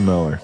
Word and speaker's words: Mueller: Well, his last name is spelled M-U-E-L-L-E-R. – Oh, so Paul Miller. – Yeah, but it's pronounced Miller.